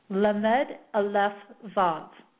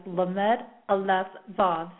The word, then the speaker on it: Lamed Aleph Vav: Now, the way that this name is pronounced, the banishing the remnants of evil, is pronounced Lamed Aleph Vav. So again, that is Lamed Aleph Vav.